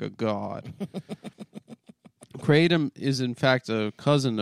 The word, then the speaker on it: Kratom: Speciosa, pronounced Speciosa, and Kratom, pronounced Kratom.